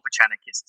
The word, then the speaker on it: Vucenic: they all say that it's not Vucenic. So I'm saying it wrong, so don't go off what I say.